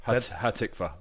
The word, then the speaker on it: Hatikvah: You did, yeah. It's almost rare for an American to say it right the first time. It means the hope. Hatikvah, the hope.